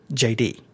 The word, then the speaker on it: Jayde: It's J-A-Y-D-Y or something like that. No, or J-A-D-Y-D-E-E. Is that what it is? – J-A-Y-D-E. – Phonetic. – It's a phonetically J-D.